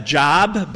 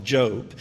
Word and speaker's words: Job: Some would pronounce that Job, but we would call it Job.